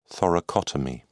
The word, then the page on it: thoracotomy: British English